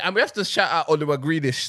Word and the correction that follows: Grealish: still. – Grealish is cold though. – Yeah. No. Say his name properly. Oliwa Grealish.